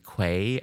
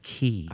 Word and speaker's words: Quay: I mean, it's not my language, but to watch American tourists, I'm being rather disoriented and say, can I go to Surrey Quay? Be politely corrected by English. It's pronounced as Key.